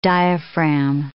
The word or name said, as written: diaphragm